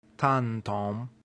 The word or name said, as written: tantum